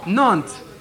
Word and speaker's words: Nantes: How do I say it? Nantes? Nantes? No. Nantes. Thank you.